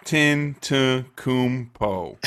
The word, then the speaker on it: Giannis Antetokounmpo: Giannis Antetokounmpo. – There we go. You get an applause one for that one, because I sure as hell would have butchered that. – Antetokounmpo.